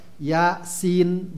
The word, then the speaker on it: Ya Sin: Jadi bacanya itu sesuai dengan huruf hurufnya utuh ya jadi nggak kita baca ha kok nggak ha ain kof gitu ya. Ya sin bukan sa si su bukan.